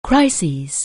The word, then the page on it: crisis: anglais